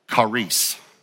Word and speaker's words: charis: I'm going to pronounce it in Greek first, and then I'll tell you our English pronunciation. But it's the Greek word charis, which we pronounce it charis, C-H-A-R-I-S, charis.